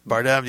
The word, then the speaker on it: Bardem: Javier Bardem, I can't pronounce his name right. – Bardem, yes.